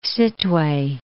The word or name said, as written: Sittwe